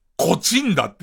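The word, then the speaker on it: 東風平: 東に風に平って書いて、こちんだって読むんですけど、そのこちんだ村営球場ってところで練習をしてますよ、つって。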